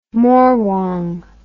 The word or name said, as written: morwong